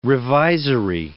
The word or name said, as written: revisory